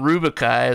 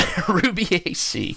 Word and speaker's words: Rubiaceae: I keep saying Rubicae as I read it, and then I looked it up, and I didn't memorize how to pronounce it. But Rubiaceae.